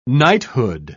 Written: knighthood

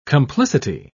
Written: complicity

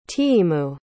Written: tiimu

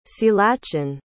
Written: ceallachan

